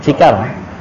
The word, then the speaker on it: Cikarang: Ci ah itu enggak ada huruf ci, ya udah ganti ji. Nah, di Cikarang enggak. Silakan sesuai pengucapan penduduk asli sini. Cikarang.